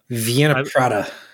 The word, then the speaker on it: Wiener Prater: How do you say that? – Wiener Prater.